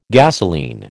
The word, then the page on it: gasoline: American English